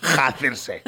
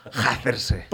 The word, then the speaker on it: hacerse: It's very, very funny when you're trying to pronounce an H, a mute H, you do hacerse.